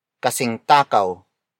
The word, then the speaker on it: kasing takaw: Kasing takaw ni Robert si Paolo.